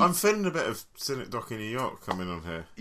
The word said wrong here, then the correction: synecdoche: Yeah, your pronunciation as ever is imperfect, but yes. – Cynic Docky is not imperfect, because I've looked at... – Synecdoche is the name of that. – Synecdoche, I'm sure.